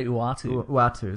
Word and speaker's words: Uatu: I say Uatu.